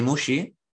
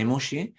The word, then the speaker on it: Muxi: Entonces, mi apellido es, yo me llamo Dani Mushi, o me hago llamar Dani Mushi, y mi apellido se escribe Muxi, M-U-X-I.